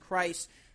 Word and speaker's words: Kreis: Bring in Jason Kreis. I pronounced that right, correct? – Yes, sir.